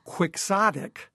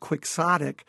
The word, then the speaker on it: Quixotic: So the name Quixotic, we pronounce it differently now, but Quixotic means someone who's very,